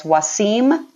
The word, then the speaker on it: Wasim: But he is branded as Wasim the Dream. And the reason is so that you get that mnemonic device, meaning the rhyme, which helps you pronounce his name correctly.